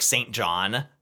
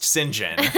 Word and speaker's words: St. John: And so it's St. John, which, by the way, is spelled like St. John, but I found out is pronounced St. John.